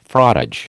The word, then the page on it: frottage: American English